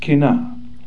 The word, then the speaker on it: qanna: The Hebrew word for jealous is kana, Q-A-N-A. Some spellings are Q-A-N-N-A. It's pronounced kina.